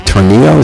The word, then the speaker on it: Tornillo: She has just returned from Tornillo, is that how you say it? – Yep.